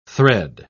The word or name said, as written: thread